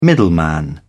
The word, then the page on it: middleman: British English